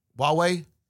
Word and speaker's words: Huawei: Hawaii, how do you pronounce that? Huawei? – Huawei, yeah. Yeah, no, Huawei's the big boy.